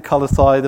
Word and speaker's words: Colossae: We had a discussion earlier in the week, Ben and I, about how you pronounce Colossae. I'm going to stick with Colossae. There may be other ways to pronounce it.